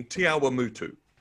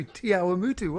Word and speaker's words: Te Awamutu: So X Electrical in Te Awamutu. – Wait, you pronounced that right. Well done. You, Te Awamutu, well done.